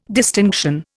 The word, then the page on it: distinction: Indian